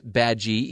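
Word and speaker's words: Badguy: It's pronounced Badgie, even though if you look at his business card, it says Dominic Bad Guy, a dead giveaway that Ricky Gervais's character is going to be the bad guy in the movie.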